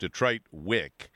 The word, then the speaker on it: DTRT WIC: Doug Schwartz is the owner of DTRT WIC, but it's pronounced Detroit WIC, and Trip Wipes.